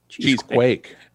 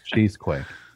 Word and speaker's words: Cheesequake: Cheesecake. Is that how you say it? Cheesecake? – Cheesequake. – Cheesequake.